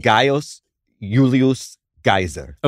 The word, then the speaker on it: Gaius Julius Caesar: No, I said Gaius Julius Caesar. – Okay. And is that correct, Luke? – For a classical Latin pronunciation, I think that's very good.